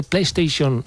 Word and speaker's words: PlayStation: ¿Te has dado cuenta que PlayStation se pronuncia en una sola palabra? PlayStation.